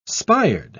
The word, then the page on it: spired: British English